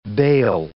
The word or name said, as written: baal